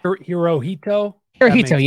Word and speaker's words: Hirohito: Emperor Japan. Hirohito. – Yes. So yeah. I did get it right.